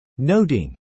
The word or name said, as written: noting